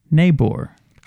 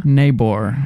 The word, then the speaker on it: Niebuhr: Niebuhr. Well, it's like a German last name, and I know I'm not pronouncing it right.